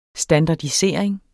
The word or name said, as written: standardisering